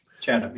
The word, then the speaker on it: Chatham: He joined the International Relations Department at Duquesne University as an instructor of Arabic in the fall of 2017, and Chatham, I hope that's pronounced Chatham? – Chatham, exactly.